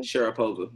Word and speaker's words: Sharapova: Sharapova, Maria Sharapova.